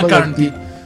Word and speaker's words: McCartney: Me equivoqué, pronuncié mal el apellido de Paul McCartney. – No, es Paul McCartney. Quedó como Paul McCartney. McCartney, sí.